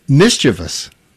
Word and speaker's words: mischievous: Mischievous. – Okay. – The correct pronunciation is three syllables, not mischievous is what people will say. – Right. – It's mischievous.